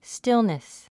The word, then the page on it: stillness: stílnis; スティルネス